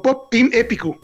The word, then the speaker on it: Pop Team Epic: Pop Team Epic o Pop Team Epicu, así se, así como lo pronuncian los japoneses.